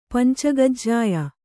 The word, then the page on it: ಪಂಚಗಜ್ಜಾಯ: pancagajjāya